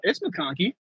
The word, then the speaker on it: McConkey: We got our boy Ladd McConkey. I don't even know if I'm saying that right because I'm overtired. – I'm pretty sure. – No, it's McConkey.